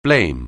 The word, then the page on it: blame: anglais